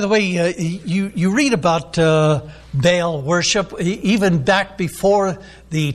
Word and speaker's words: Baal: The way that it's spelled B-A-A-L, usually we pronounce it Baal in English, but the Hebrews, when they pronounce it, they split it up into two syllables.